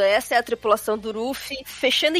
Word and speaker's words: Luffy: E assim, quando a gente lançou na Conrad, a nossa tradutora Drixada, ela falou assim, ó, a pronúncia é exatamente como a Pá fez. É, é Ruffy. Ruffy.